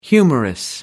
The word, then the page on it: humerus: American English